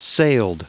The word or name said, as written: sailed